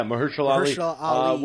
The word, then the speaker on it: Mahershala Ali: Mahershala Ali. Am I pronouncing his name right? I don't want to mess that up. – Yeah, Mahershala Ali.